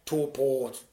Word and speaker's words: Taupo: I kept getting it wrong way back in the day. And they said, you need to change. – Yeah. – And so I need to change, but my God, I butchered it for 6 months. Taupo, and I only get it now, but I have to consciously say it to myself almost in advance of saying it every single time.